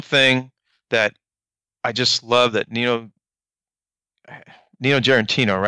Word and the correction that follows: Giarratano: right? Did I say that correctly? – Nino Giarratano. – Giarratano.